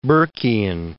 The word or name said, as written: Burkian